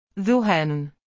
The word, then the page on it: zehaan: arabic